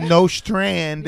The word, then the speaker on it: Nostrand: Who pronounce it Nostrand. – Yeah, that's how you say it.